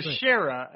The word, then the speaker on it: Teixeira: It's Teixeira. Yeah. – Yes. Teixeira.